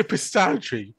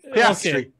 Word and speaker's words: Piastri: Oscar Piastri. Piastri. – Yeah, not... – Oscar Piastri.